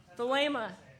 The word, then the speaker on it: Thelema: Was it Thelema or Thelema? – Thelema. – All right, so he was into Thelema. – It's not Thelema.